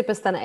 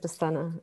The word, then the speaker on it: Pestana: But you can say Pestana. Everyone says Pestana. It's okay. Don't worry.